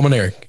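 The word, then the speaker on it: Mainieri: Is that how you say it? – Yes, Paul Maneri.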